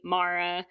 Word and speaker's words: Mara: And already Mara, M-A-R-A, all the time I get people like, oh, it's Maria, there's a U in it, all sorts of stuff.